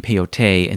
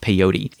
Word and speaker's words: peyote: It's called peyotl in Nahuatl, in the ancient Mesoamerican language, which then, you know, in Spanish became peyote, and then in English it's usually pronounced peyote.